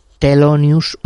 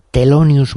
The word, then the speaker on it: Thelonious: Thelonious Monk, con T de turrón, pero se escribe con TH. Thelonious Monk.